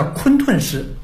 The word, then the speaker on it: 混沌氏: 嗯，盘古开天地，盘古的姓氏叫盘古氏，又叫做混沌氏，但是那个字的古读音呢叫昆沌氏，就是讲的一个天地的一个，就是你这个在线条上。那么这个制作工艺呢就更加的复杂，面板全部用青铜，北宁之前的老的青铜，用菱形的木块拼接而成。